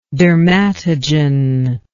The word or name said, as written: dermatogen